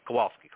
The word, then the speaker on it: Kowalski: Kowalski, correct.